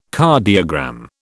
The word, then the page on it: cardiogram: British English